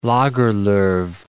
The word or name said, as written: Lagerlof